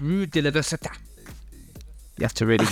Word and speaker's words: Rue de l'Université: It's called Rue de l'Université.